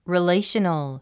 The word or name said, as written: relational